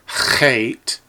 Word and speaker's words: chet: It's a chet, like C-H-E-T, but with a rasping ch sound, like in the word Bach, the German composer, Johann Sebastian Bach.